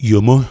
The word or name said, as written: Yomo